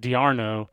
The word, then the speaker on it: d'Arnaud: So, but I mean, Kyle Drabek, Michael Taylor, Travis d'Arnaud, I guess it's actually pronounced d'Arnaud, Zach Collier, J.A.